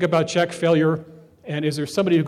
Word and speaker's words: Fellure: Mr. Failure, it's spelled F-E-L-L-U-R-E, Failure. He's been running since 1988,